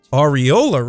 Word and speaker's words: Ariola: A-R-I-O-L-A. I would really like to pronounce that a different way. That is an anatomical term, but Ariola, I believe, is how it's properly pronounced.